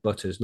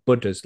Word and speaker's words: Buddha's: Somebody said Butters. No, not Butters. Buddha's, like Buddha, the guy who meditates, you know.